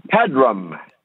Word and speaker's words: Peram: Pebram. – Okay. – P-E-R-A-M. Like Pedro, only with rum at the end.